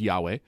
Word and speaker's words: Yahweh: the letters YHWH. Usually that's pronounced Yahweh,